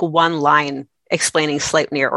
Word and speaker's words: Sleipnir: Sleipnir, the eight-legged stallion.